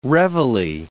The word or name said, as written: reveille